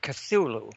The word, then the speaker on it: Cthulhu: I pronounce it Cthulhu.